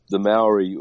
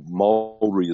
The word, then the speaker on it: Māori: Like the Maori or Māori, as they meant to, you supposedly pronounce it, had a massive.